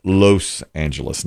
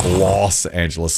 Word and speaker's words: Los Angeles: And the other one said, oh, you're saying it wrong. It's Los Angeles, not Los Angeles.